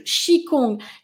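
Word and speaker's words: qi gong: Stéphanie qui se dit ça se prononce Chokong. Eh bien, Chikong, Chikong.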